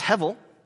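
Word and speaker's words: Hevel (הבל): Abel, his name is really in Hebrew, it's pronounced Hevel.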